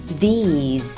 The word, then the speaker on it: these: 要注意 these 这个单词的发音 TH 需要轻轻咬一下舌尖，中间的 E 要微笑， E 结尾的 z，对，要准确地发出来，再来 z，好，连起来就是 these，很好，these these 表示这些东西，比如说这里的这些芒果，these mangoes。